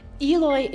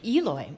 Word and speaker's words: Eloy: Eloy, Arizona. – Oh. How do you spell it? – E-L-O-Y. – Oh, that's how you would say it.